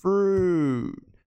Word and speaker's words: fruit: Fruit. Fruit é fruta. Fruit não é fruit. Não tem o i, não é pronunciado.